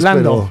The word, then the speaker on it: Landau: No sé cómo se pronuncia en inglés. – Landau, pero, pero es como es un apellido alemán, confunde.